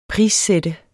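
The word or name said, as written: prissæt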